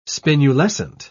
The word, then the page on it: spinulescent: British English